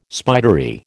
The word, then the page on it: spidery: American English